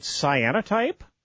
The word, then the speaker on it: cyanotype: Is it Cyanotype with Jessica Kirby? – Yes, you are pronouncing it correct.